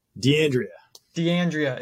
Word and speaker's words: D'Andrea: D'Andrea. – It's Italian. I knew that. I should have got that right.